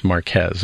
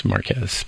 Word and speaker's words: Marquez: but I heard clearly that the name is pronounced Marquez. A lot of people ask, how do you pronounce MKBHD's name? And that's Marquez Brownlee.